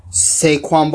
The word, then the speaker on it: Saquon: This is Saquon? – Saquon Barkley.